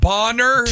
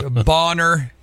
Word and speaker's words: Bonner: For those of you north of town, it is pronounced Bonner. – Two N's. – Right, Bonner.